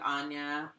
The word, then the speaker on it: Aine: So The Way Up is like about a woman named Anya, which in she's Irish, so of course it's spelled A-I-N-E,